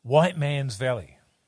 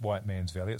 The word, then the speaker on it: Whiteman's Valley: It's called White Man's Valley. – Is it seriously called that? – It's called White Man's Valley. Now, it's not pronounced White Man's Valley.